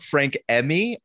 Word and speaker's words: Frank Emi: Listen, Kira, you're doing this episode, we're talking about Frank Emmy. I believe that's how I've been pronouncing it. – Yeah, so far as I can tell.